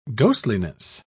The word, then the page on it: ghostliness: British English